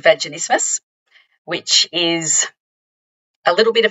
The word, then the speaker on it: vaginismus: I'm wondering too, and not to jump the gun here because you might have this slated to chat about later, and I'm going to mispronounce this, but is this where that condition, is it vaginismus? – Vaginismus. – Yes, thank you. I always pronounce that wrong.